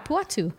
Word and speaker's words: Puatu: Portrayed by Jacqueline Pinol and Griffin Puato. I don't know how to say his last name. Puato? – Puatu? Puatu? – Puatu? – Yeah, Puatu. Griffin Puatu.